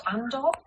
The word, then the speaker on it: Andor: Am I saying it right for a start? Andor? Yes.